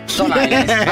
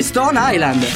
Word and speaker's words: Stone Island: Questi jeans della Stonehenge. – Di jeans, ma che c'è la... – Questi jeans della Stonehenge. – Stone Island. – Ma perché scrivono come si legge? – Si chiama così, Stone Island.